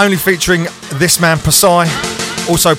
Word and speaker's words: Posij: Now, his name might be spelt P-O-S-I-J, but you pronounce it Posai.